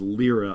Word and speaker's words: Lyra: That's the scene which features a younger Krennic with darker hair, as well as Galen and Lyra, and it is Lyra on the pronunciation, and a very young Jin being put back to bed by Galen.